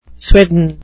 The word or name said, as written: swidden